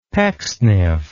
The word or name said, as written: Pecksniff